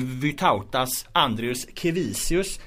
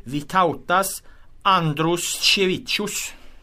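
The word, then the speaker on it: Vytautas Andriuškevičius: Jag var väldigt tvärsäker på att, att man säger Vytautas Andrius Kevisius. Men det här fick vi ju snabbt backning på, på Twitter från Emma Lukins som ju är eh, på TT va? Hon är TT-reporter. TT-reporter och, och. Eh, uh, har koll på det här. Hon hävdar här då att det korrekta uttalet uh, på, på spelarens namn är Vitautas Andros Cevicius Mm.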